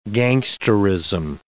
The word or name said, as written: gangsterism